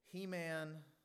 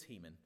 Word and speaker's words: Heman: Heman, that's how I have to pronounce it, sorry. It's Heman.